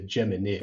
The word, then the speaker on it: Geminids: Yeah, so the constellation is Gemini, but the meteor shower is the Geminids. – The Geminids. – So it's not a long I anymore.